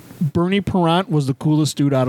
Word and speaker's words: Parent: Parent, as in parent. – Oh. No, I missed. – But it's the French pronunciation.